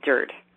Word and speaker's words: DIRTT: All right, today I had a meeting with the president of a company called DIRT, which is spelled D-I-R-T-T, mm-hmm.